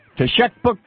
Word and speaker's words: Teshekpuk: Teshekpuk Lake in northern Alaska, it's pronounced T-E-S-H-E-K-P-U-K.